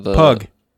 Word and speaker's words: Pugh: Florence Pugh, or however you pronounce her name. It's a good one. It's about the Pug.